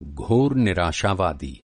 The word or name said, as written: निराशावादी